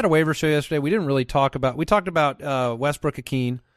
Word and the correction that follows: Ikhine: How do you pronounce it? – Westbrook Ikhine. – Ikhine, not Akeen.